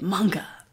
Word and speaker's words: manga: Apparently, that's how you say it. Not manga, you say it manga.